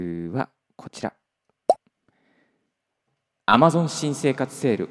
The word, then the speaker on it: Amazon: 発音があですね。 Amazon、 Amazon って言った低いところ、低いところから入りますけど、 Amazon ですね。